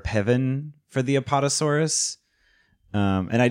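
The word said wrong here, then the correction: pavane: Am I pronouncing it right? Pevan? – Pavan? – Pavan.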